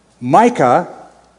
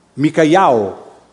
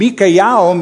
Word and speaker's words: Micayahu: Micah, or Micah, actually the Hebrew pronunciation is not Micah, but Micayahu. Micayahu. And Micayahu means "who is like God."